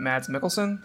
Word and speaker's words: Mads Mikkelsen: Mads Mikkelsen. Is that right? Yeah. – That's as good as I know it to be.